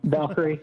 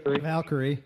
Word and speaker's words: Valkyrie: The IJ Valkyrie, or I don't even know if I say that right, but yeah, that one. – Valkyrie. – Valkyrie.